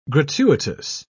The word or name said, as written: gratuitous